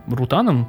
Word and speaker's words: Рутан: И они вместе с Бертом Рутаном, по-моему, так у него ставится ударение,